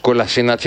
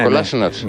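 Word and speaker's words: Kolašinac: Κολάσινατς είναι, όχι Κολάσινατς. – Ναι, θα πω με προφορά, Κολάσινατς είναι.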